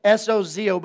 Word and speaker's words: sozo: The word saved in Greek is sodzo sozo. Okay, it's spelled S O Z O, but it's pronounced sozo.